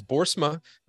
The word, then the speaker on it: Boersma: And today I have Dr. Hans Boersma with us as our guest. I'm going to introduce him here really quickly. Dr. Boersma, and I am saying that right, is that correct? – You okay.